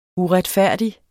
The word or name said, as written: uretfærdig